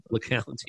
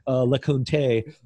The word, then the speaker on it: LeCounte: LeCount, yeah. Yeah, not the French pronunciation I like to give him. LeCounte, or whatever I said last show.